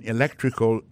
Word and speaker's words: electrical: Également vient dans electrical engineering, accent tombé sur deuxième syllabe ça.